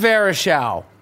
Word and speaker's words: Varejao: But Anderson, how do you say his last name? Varejao. Anderson Varejao.